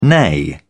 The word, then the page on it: neigh: British English